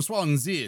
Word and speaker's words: Swansea: You can't say Swansea without doing the accent. He's from Swansea. It's got the extra syllable as well.